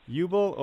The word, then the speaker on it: Ubl: It's either Ubel or UBL or Ubel? – Hey, yeah, you got it right the first time. It's Ubel.